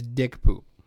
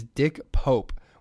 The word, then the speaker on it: Dick Pope: Accidentally mispronounced the name of Mr. Turner's cinematographer as Dick Poop. His real name is Dick Pope, which provides its own uncomfortable mental image.